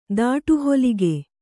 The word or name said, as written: ದಾಟುಹೊಲಿಗೆ